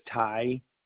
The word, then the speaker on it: Tygh: First name is Ty, last name is Outland, and it's spelled TYGH.